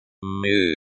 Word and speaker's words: Мы: Mu. Pronounced like my, but with a soft u.